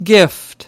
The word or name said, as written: Gift